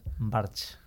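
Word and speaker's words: Barx: ¿Cómo, lo, pronuncias? – Barch. – Barch, sí, vale.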